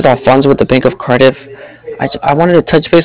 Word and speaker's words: Cardiff: Yeah, Cardiff. It's spelled C-A-R-D-I-F-F, like different, like Cardiff.